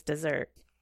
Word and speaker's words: Mount Desert: Is it pronounced Mount Desert or Mount Desert? The research I've done says it's desert.